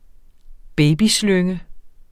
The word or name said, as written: babyslynge